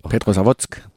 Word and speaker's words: Petrozavodsk: Wir haben aus Petrozavodsk. – Petrozavodsk.